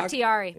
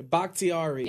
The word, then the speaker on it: Bakhtiari: I thought he chugs beers, no, no, not, not Bacardi's. Bacardi. Uh, Bak- Bakhtiari. – Bakhtiari.